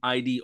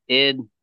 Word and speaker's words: ID: It's pronounced id as one word, obelus. – It Obelus? Okay. – It Obelus, yeah. It's not an act. Well, it was an acronym, but it's always been pronounced id.